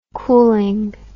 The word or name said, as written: coolingness